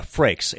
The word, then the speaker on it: Frakes: but was Jonathan Frakes or Frakes? How do you pronounce his name? – Yeah, Frakes. Yeah,